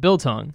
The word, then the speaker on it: Biltong: Did I say that right? Biltong. – Absolutely perfect.